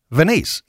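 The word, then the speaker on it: Venise: It's not Venice. It's V-E-N-I-S-E.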